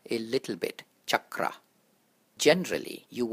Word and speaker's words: chakra: The spelling for this word is C-H-A-K-R-A. Now in this one, both the A's are short. And the CH is pronounced as how you would pronounce the CH in the word chat, C-H-A-T, chat, not how you would pronounce shin or shout. So pay attention to how I pronounce the A's and the C-H. Chakra, chakra, as opposed to chakra.